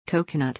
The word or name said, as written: coconut